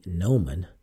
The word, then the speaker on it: gnomon: Gnomon is spelled G N O M O N. That is G N O M O N. So it's not, the G is silent, so that's a little tricky.